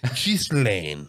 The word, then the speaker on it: Ghislaine: He's like, this is how we pronounce Ghislaine. – Yeah, I'm going to go with that one. – Yeah, that guy sounds legit. I'm going to go with Ghislaine.